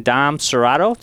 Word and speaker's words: Dom Serrato: We also honored today to have Deacon Dom Serrato. Did I say that right, Dom? – That sounds good.